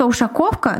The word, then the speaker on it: Ушаковка: Речка Ушаковка на самом деле эм, Спасибо большое, Марина, за ударение в названии речки.